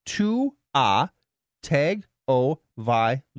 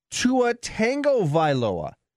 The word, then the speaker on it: Tua Tagovailoa: His name is Tua Tagovailoa." And then he goes on to spell it phonetically. Tua Tagovailoa. "Yet you two buttholes regularly and continuously mispronounce it. When you say Tua Tagovailoa, there's no tango in his name.